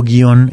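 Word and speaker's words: guion: Sin embargo, guión es un monosílabo, tiene una sola sílaba.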